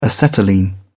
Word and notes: acetylene: British English

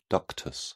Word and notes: ductus: British English

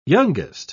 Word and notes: youngest: British English

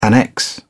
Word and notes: annex: British English

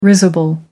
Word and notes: risible: American English

